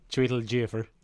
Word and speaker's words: Chiwetel Ejiofor: Yeah, that was just about to, there's one scene where Chiwetel Ejiofor. – Can you say his name for me? – Chiwetel Ejiofor.